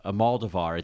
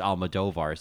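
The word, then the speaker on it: Almodóvar: And in my kind of tiptoeing into the world of Pedro, and I finally learned that his last name is not pronounced Almodovar, it's Almodovar.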